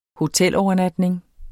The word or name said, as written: hotelovernatning